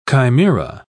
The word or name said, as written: chimera